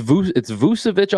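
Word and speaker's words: Vukčević: I know the first guy that you have in here, Tristan Vukcevic. Am I saying that right? It's not quite Vucevic, but it's Vucevic. – Yeah, it's Vucevic almost with a K in there, just for fun, just for shits and giggles.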